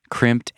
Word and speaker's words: Crimpd: Crimped has you covered. So check it out. Crimped is spelled C-R-I-M-P-D. That's crimp with a D at the end.